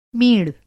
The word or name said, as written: मींड़